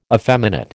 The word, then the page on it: effeminate: American English